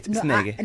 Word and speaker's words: Isnege: Again, the Xhosa in my ear just pronounced it completely differently. – No, you pronounced it right. Isnege. – No, I pronounced it right. She leaned on a different kind of emphasis. – Okay. – On a different syllable.